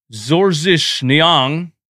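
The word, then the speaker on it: Georges Niang: Doc Rivers went with George Niang to pronounce it in his father's native language, Zorzish Niang.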